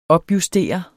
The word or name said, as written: opjustere